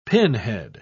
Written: pinhead

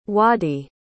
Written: waddie

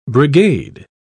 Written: brigade

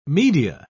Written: media

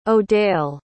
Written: odale

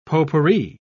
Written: potpourri

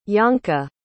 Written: Janka

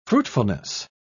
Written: fruitfulness

